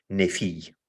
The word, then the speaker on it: nefy: N-E-F-Y diye yazılır nasıl telaffuz edilirse. Nefi ya da.